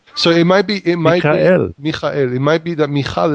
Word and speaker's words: Michal: And now Michael, is that how you pronounce it? Michael? – Michal. – Michal.